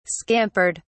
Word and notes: scampered: British English

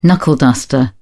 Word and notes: knuckleduster: British English

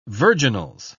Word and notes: virginals: British English

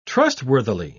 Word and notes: trustworthily: British English

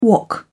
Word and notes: wok: British English

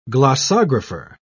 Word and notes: glossographer: British English

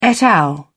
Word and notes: et al.: British English

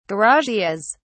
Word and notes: garages: British English

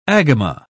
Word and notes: agama: British English

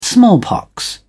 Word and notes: smallpox: British English

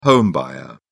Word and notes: home buyer: British English

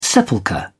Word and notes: sepulchre: British English

